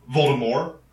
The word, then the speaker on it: Voldemort: Voldemort? Who is more Voldemort? – It's from French. The T is silent.